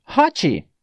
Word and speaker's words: hot: Eu vou falar o seguinte, hot, que é como nós brasileiros falamos essa palavra. Nós criamos um i para esse t, mas ele não existe, sendo que a pronúncia é hot.